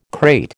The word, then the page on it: crate: American English